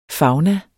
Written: fauna

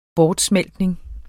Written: bortsmeltning

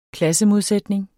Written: klassemodsætning

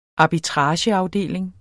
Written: arbitrageafdeling

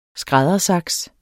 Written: skræddersaks

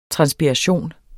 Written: TRANSPIRATION

